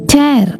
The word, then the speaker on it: tear: TEAR എന്ന വാക്ക് ടേർ എന്ന് വായിച്ചാൽ കീറുക എന്നാണ് അർത്ഥം. ഇതേ വാക്ക് തന്നെ TEAR ടിയർ എന്ന് വായിച്ചാൽ കണ്ണുനീർ എന്നാണ് അർത്ഥം.